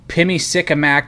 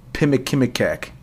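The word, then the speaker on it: Pimicikamak: You know what else is pretty cool is the fact that I'm looking at this word and you actually pronounced it right, because as we know with me, there ain't no Pimikimikek.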